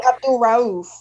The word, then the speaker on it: Abdul-Rauf: Mahmoud Abdul-Rauf, or I always say his name wrong. – Abdul-Rauf. – Yeah, Rauf. Yeah.